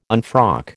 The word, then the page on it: unfrock: American English